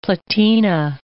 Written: platina

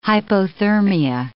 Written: hypothermia

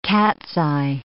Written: cat's-eye

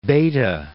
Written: Bethe

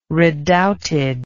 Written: redoubted

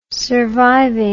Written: surviving

